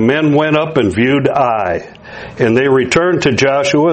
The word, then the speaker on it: Ai: It's spelled A-I. I've always pronounced it Ai, and I believe that's correct.